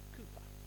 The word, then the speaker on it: Cowper: His name looked like Cowper, it's actually pronounced Cooper.